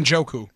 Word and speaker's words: Njoku: Njoku.